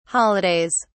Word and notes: holidays: British English

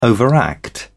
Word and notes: overact: British English

